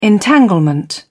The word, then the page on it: entanglement: British English